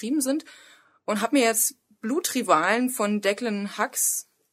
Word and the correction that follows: Hughes: oder wie auch immer der ausgesprochen wird, ähm, bestellt. – Hughes, würde ich mal sagen. – Declan, Declan Hughes. Declan Hughes. Sagst du?